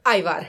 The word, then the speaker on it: Ajvar: How do you say Ajvar? – Ajvar.